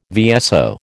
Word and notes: VSO: American English

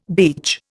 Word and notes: beech: Indian

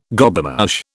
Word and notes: Gobemouche: British English

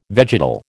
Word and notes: vegetal: American English

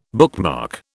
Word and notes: bookmark: British English

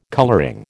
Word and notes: colouring: American English

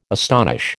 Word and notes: astonish: American English

